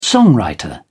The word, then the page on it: songwriter: British English